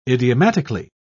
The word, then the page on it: idiomatically: British English